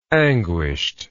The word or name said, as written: anguished